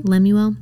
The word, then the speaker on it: Lemuel: Lemuel.